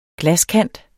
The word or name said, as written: glaskant